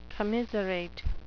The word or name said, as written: commiserate